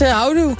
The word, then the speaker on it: houdoe: Het is houdoe. Houdoe. – Houdoe. – Niet houdoe. – Ja. Nou, sterker nog, we werden al gelinst in de app. Want Harco die zegt inderdaad, de klemtoon moet op hou liggen.